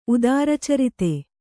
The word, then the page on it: ಉದಾರಚರಿತೆ: kannada